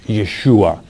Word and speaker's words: Yeshua: It's transliterated into English, the spelling Y-E-H-O-W-S-H-U-W-A, pronounced Yeshua.